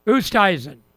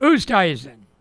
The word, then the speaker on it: Oosthuizen: Oosthuizen. – I see why you call him Toaster. – Oosthuizen.